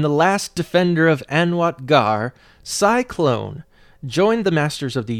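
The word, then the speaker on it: Sy-Klone: His name is Cyclone. Let me spell that for you. S-Y hyphen K-L-O-N-E.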